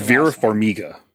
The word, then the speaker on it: Vera Farmiga: Vera Farmiga.